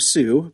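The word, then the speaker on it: Tsu: Now another new social network named, they say it's a social network named Sue, but it's spelled like Tissue. Tissue.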